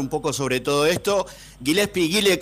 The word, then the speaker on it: Gillespie: así pronunciado Gillespie,